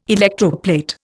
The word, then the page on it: electroplate: Indian